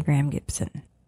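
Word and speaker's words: Graeme Gibson: I'm going to say Graham Gibson. But it's spelled Graeme.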